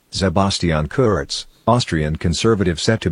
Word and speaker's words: Sebastian: So not Sebastian, but Sebastian.